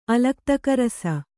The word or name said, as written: ಅಲಕ್ತಕರಸ